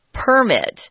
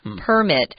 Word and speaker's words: permit: The verb is permit. The noun form of this word is permit. Notice the 重音 is on the first syllable with the noun, permit.